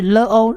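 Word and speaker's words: lo (לא): lamed 呢发音上是发 l 的音，但是因为它左上方有一个黑点是长母音 o，所以是 lo lo kilo lo lo。而第二个字母呢是末音字母 aleph 呢它不发音，所以它只发 lo 的音 lo kilo，因为不怎么样。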